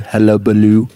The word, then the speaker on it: hullabaloo: And this is another reason why I don't necessarily believe in all this hubbubaloo. How do you say that? Hubbaloo, hullabaloo. – Hullabaloo. – Hullabaloo. Hullabaloo. Yeah.